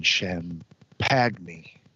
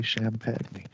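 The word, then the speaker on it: Champagnie: Julian Champagny. That's how some people pronounce champagne. But that exactly. guy's name, I think, is actually Champagne.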